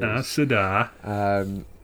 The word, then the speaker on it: Aes Sedai: It's Aes Sedai. And I've always just pronounced it as Aes Sedai or Aes Sedai. Like, it seems the pronunciation is a lot longer, like you pronounce a lot more of the sounds than I would.